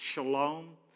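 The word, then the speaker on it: shalom (שלום): And the word peace, we in Hebrew, it's pronounced shalom.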